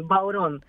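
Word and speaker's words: bodhrán: Μπαουρόν.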